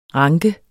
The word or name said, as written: ranke